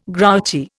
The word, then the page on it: grouchy: Indian